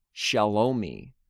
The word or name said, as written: Shelome